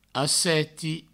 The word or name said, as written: asseti